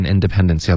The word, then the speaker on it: independence: 对，whole 跟 independence 要连读。 This whole independence thing。 – 您在教我们读那独立那词儿，很多同学读不清楚。 – OK，这个字的发音是 independence。 OK，结尾这个 n 的音，注意舌尖得顶上颚。